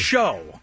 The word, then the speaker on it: Schau: It's Steve Schau. – It's spelt show, said show. Chow. – Yeah. Show, but there's no E on the end.